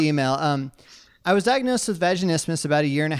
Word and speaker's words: vaginismus: This this concerns a condition called vaginismus. Am I saying that right? All right, cool. – You are vaginismus. Yes.